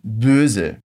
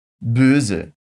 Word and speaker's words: Böse: Aqui a gente tem o som de O, umlaut, que é aquele O com biquinha que nós temos no alemão. Repete comigo. Böse. Böse.